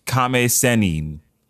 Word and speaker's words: Kame Sennin: Well, I can, if I can, it's pronounced. – And you may. – Kame Sennin. – Ooh, thank you.